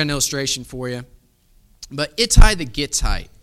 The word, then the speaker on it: Ittai: His name is Ittai.